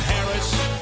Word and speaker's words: Harris: Word salad Harris. – Oh, yeah. – It rhymes with embarrass.